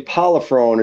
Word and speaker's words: Prollifrone: Yeah, it's actually Prollifrone is the official last name, but I go by Frankie Provolone.